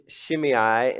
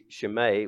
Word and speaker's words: Shimei: And as he cursed Shimei, and that's the English pronunciation of that. If you were going to do it in Greek, it would be Shimei, which we, I don't know, Shimei, yeah, whatever.